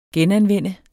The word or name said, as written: genanvende